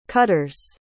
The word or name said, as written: cutters